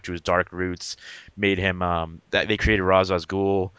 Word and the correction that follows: Ra's al Ghul: Ra's al Ghul, sorry. – That's right. – I corrected myself. I'm like, oh crap, I said it wrong.